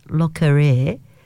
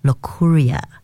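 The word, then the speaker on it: Locorriere: When the news broke last week that Denis Le Courier, or I should say Le Courier, yes, I've been practicing Le Courier, that's how he pronounces it.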